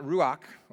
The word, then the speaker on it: ruach: It's spelled R-A-U-C-H, R-U-A-C-H. But it's not ruach or ruach. It's in Hebrew, it's ruach.